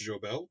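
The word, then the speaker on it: Goebel: Göbel, or sometimes pronounced Jobel.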